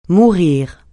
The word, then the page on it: mourir: francais